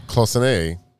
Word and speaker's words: cloisonné: Cloisonné.